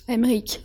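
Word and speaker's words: Aymeric: Emmerich.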